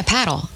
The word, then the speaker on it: padel: Have you played padel or paddle? I've heard various pronunciations. – I don't know what paddle. What? Padel? – Yeah, paddle. It's just paddle.